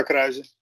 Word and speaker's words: Kruijzen: I'm not going to try to say my name in English. Edgar Kruijzen on Twitter, on Instagram, and everywhere.